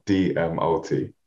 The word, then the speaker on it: DMOT: So my surname is actually pronounced DMOT, exactly how it's spelled, DMOT.